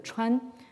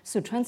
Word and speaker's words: Sichuan: Dans chinois moderne, cette partie-là, prise indépendamment, prise isolément, se prononcerait donc Chuan c'est le Chuan que vous avez dans, le nom de la province du Sichuan. Sichuan, ça veut dire la province des quatre, des quatre fleuves ou des quatre rivières.